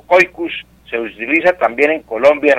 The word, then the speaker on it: caucus: Pero el caucus, que se pronuncia coicus, ¿no? La pronunciación es coicus,